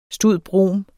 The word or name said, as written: stud.brom.